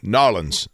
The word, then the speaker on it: New Orleans: We're going to go to New Orleans, or Narlins. See, I said it right that time. Narlins. Todd got all over me.